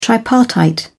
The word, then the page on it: tripartite: British English